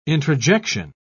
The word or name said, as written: introjection